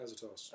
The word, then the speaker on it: Azotus: Azotus. – Azotus.